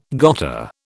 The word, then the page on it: gotta: British English